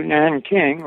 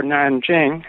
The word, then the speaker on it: Nanjing: And they went to China in 1930, and they spent their first 2 years studying Chinese intensively in Beijing, and then moved to Nanking, or Nanjing, as it should correctly be pronounced, in 1932, and I was born there in 1933.